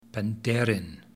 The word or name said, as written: penderyn